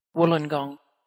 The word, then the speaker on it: Wollongong: Zo spreek je het uit. Wollongong.